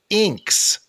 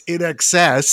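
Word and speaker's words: INXS: Of course, nobody really knew how to say the name, so most people were saying Inks. So I really liked the band Inks. And thankfully, I had seen the VJ say it's In Excess, so I had gone to school, and there was some kid was like, "Did you watch MTV yesterday?"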